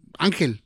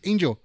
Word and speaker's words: Angel: So for me, it's just like, just fucking call me Angel. So in Spanish, it's like Angel. You know, in English, it's Angel.